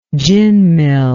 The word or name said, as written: gin mill